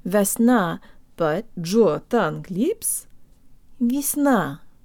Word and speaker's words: весна: guys, today you'll learn how to pronounce the word весна, or spring. The first y sounds like an e, and the v has a hint of a y sound in it.